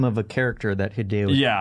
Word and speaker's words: Hideo: I thought it said Hido. Yeah. Like, I didn't know how to pronounce it. I thought it was the word hide, and I was like, am I supposed to hide?